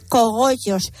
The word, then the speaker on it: cogollos: habéis puesto cobollos. Y nos quedamos así, dice mi cuñada, ay, perdona tía, pero es que no se dice cobollos, se dice cogollos.